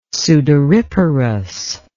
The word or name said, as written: sudoriparous